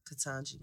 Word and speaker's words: Ketanji: Ketanji Jackson Brown. Am I saying her name right? – Ketanji.